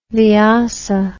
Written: VIASA

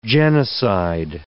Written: genocide

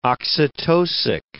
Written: oxytocic

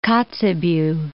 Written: Kotzebue Sound

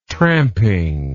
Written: tramping

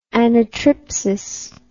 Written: anatripsis

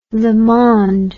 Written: Le Monde

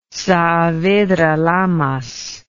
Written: saavedra lamas

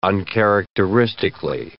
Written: uncharacteristically

